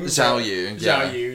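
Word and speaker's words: Xiaoyu: Ooh, that's easy, that's Jiayu. I think I'm saying her name wrong. – Zhao Yu, yeah.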